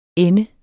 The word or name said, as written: ende